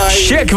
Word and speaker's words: SHQQ: Check was dat, want zo spreek je dat uit. S-H-Q-Q.